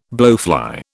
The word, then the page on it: blowfly: British English